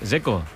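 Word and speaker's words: Džeko: A mí el que me parece difícil es el de Checo, Dec, porque DZ, ¿cómo se pronuncia? – Dzeko. Bruno dice que es Dzeko. – Como tirando a J, Dzeko o algo así.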